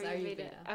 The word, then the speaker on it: Ayurveda: And so for everyone listening out there, and I might mispronounce this wrong, Ayurveda. – Yeah, it's Ayurveda.